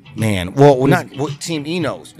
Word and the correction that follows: Ineos: now, right? Enos. – Oh, Ineos. – Ineos, okay.